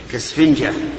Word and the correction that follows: إسفنجة: أنا أحسبيها ألف أحسبيها همزة كالإسفنجة هكذا ننطق بها.